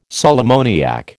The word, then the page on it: sal ammoniac: American English